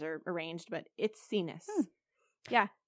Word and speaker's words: Itsines: Okay, so it's spelled Itsines. It's pronounced It's Sinus. – No way. It is. I've been saying it signs. It's Sinus. – I think I was saying like It's Knees.